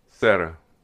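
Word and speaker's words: Serra: Sierra? – Sierra. – In 1771. And get this, even though he was a staunch defender of the rights of Indians, statues of Sierra have been recently destroyed in many towns throughout California.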